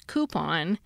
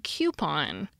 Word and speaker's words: coupon: Coupon is etymologically French, from the Old French couper, C-O-U-P-E-R, meaning to cut. Not sure if I pronounced the Old French right. But has the alternative pronunciations coupon and coupon.